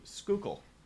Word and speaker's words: Schuylkill: Schuylkill. Schuylkill. That's how you say that area there. – That's funny. – Yeah, it's spelled S-C-H-U-Y-L... K-I-L-L, Schuylkill. A little bit of local pronunciation.